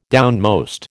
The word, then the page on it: downmost: American English